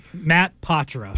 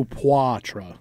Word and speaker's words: Poitras: I was trying to do Poitra. – Yeah, Poitra. – Me too. Here it is. – Patra. Right. – Don't look at the way his name is spelt. It's P-O-I-T-R-A-S.